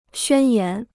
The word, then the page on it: 宣言: xuān yán